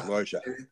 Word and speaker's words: Lorgia: You say it Lorgia, kind of like Georgia, but with an L. – Lorgia, yes, thank you.